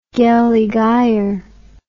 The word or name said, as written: gelligaer